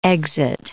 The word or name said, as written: exit